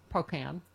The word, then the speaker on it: Pocan: Pocan. – There you go. – Representative Mark Pocan.